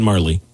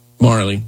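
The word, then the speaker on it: Majerle: Dan Majur, I never know how to pronounce that dude's name. – Dan Marley. – Marley, yeah, Richard Dumas, Charles Barkley, and Mark West.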